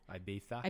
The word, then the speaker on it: Ibiza: Is how you say it? Ibiza? – Ibiza.